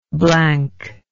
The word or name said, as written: blankness